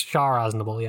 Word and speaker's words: Char Aznable: I only know him as, is it Char Aznable? Am I pronouncing that correctly? – Yeah, basically. It's Char Aznable, yeah.